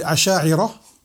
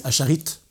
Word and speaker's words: Ash'ari'a: Donc maintenant, les Ash'ari'a, qu'on appelle Ash'ari'a, et en français ils disent Asharit, c'est la prononciation la française.